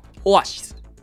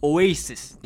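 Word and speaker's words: Oasis: さあそして今回ご紹介させていただくアーティストはですね、ビートルズの再来と呼ばれたイギリスの伝説的 5 人組ロックバンド、オアシスですね。ちなみにですね、ちなみに正しい発音はオエイシスって言うんですよ、これで。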